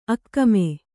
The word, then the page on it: ಆಗಾಮಿ: āgāmi